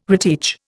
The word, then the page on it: reteach: Indian